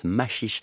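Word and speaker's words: Maxixe: Ça, s'écrit Maxix, mais ça se prononce Machiche.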